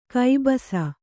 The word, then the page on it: ಕಬ್ಬಸು: kannada